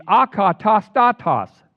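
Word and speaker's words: akatastatos (ἀκατάστατος): I love this word because of the way it's pronounced. It's akatastatos. Akatastatos. I had to practice that forever.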